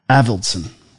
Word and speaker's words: Avildsen: Alvinson? – I don't remember. – Same director as Rocky. I just don't know if I'm pronouncing his name correctly or getting it right. John G. Avildsen.